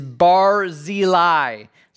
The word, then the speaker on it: Barzillai: I'll say that again and make sure we get this pronunciation right. I actually looked it up to make sure I was saying it right. Is Barzillai. So bar and then the letter Z uh, lie.